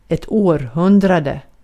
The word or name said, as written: century